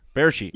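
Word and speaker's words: Bärtschi: That's why Sven – Berchi, is that how you say his name up Berchi. In Vancouver? – Berchi.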